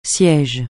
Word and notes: siège: francais

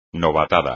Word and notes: novatada: espagnol

